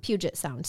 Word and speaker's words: Puget Sound: Puget Sound, sorry. I think I said it right the first time and then wrong the second time. – You did, yeah. It's Puget Sound over in Washington.